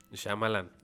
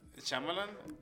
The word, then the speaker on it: Shyamalan: Shyamalan. Shyamalan. Acuérdate de chamán.